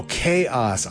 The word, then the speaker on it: k-os: After that, Crab Bucket doing, I think it's either KOS or K-OS. That's how it's spelled. We'll call it KOS. Chaos? Oh, Chaos. I get it.